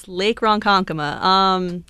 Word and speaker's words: Lake Ronkonkoma: And what about Lake, you pronounced it right before the show. I'm probably not going to be able to do it. Lake Ronkonkoma? – Yes, Lake Ronkonkoma. You did pronounce it correctly.